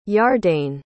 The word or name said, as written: yardane